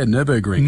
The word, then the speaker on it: Nürburgring: Yeah, I know all about, is it Nürburgring? How do I say it? – Oh, yes. Yeah, no, that's not too bad. Yeah, Nürburgring.